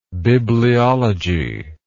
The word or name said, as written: bibliologist